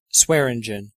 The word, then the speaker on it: Swearengen: Swinengen? – Swearengen. – Swearengen, okay. – Like swearing. – Right. – And gin. – And gin, yeah.